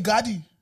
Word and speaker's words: Gardaí: So how do you say Gardaí? – Gardaí. – There's Gardaí.